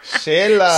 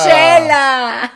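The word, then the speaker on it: Xela: ¿Cómo se pronuncia, otra Cela. – Oh, ya, lo destruyó otra vez, Miriam. Shela. – Shela.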